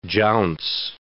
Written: jounce

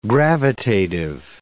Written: gravitative

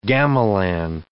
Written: gamelan

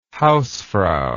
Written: hausfrau

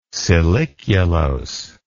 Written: siliculose

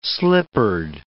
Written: slippered

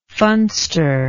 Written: funster

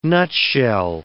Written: nutshell

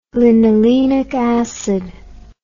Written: linolenic acid

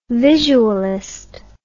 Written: visualist

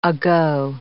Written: ago

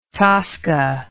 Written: tosca

